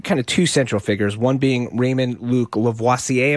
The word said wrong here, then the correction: Levasseur: He's from Maine. He just says Levasseur.